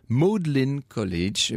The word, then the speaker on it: Magdalen College: et c'était au Magdalen College, qu'on prononce, me dit Anne, Magdalen College.